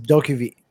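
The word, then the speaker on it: DokeV: And the other one was, I don't know how you pronounce it, but this caught your eye too, like Dokiv or something like that. – I think it's pronounced Dokiv.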